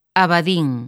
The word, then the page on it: Abadín: lingua galega